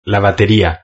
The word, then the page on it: la bateria: Spain